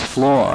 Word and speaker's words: flaw: Well, there is one flaw, and perhaps a second flaw, but one immediate flaw, Justice Souter, compliment you on the way you pronounce flaw. I didn't do quite so well earlier this morning. – We have that way in New Jersey, sir. – It rhymes with law. Law.